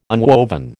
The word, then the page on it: unwoven: American English